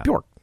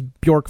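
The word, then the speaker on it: Björk: Bjork.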